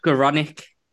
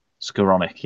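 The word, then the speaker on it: Skowronek: So it's going to be him and Ben Snokerin, or however you pronounce his name. – Yeah, you've got Ben Skoronik. – Skoronik, yes, that's the one.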